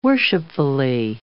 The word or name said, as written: worshipfully